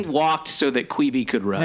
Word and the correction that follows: Quibi: Now, I noticed you feel like you pronounce it Queeby. I'm a Quibby guy. – Well, let's call the whole thing off. No, I think I am. I think I am mispronouncing it.